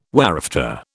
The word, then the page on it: whereafter: British English